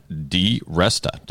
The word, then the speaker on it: DiResta: She's an expert on communications and public speaking, and her name is Diane DiResta.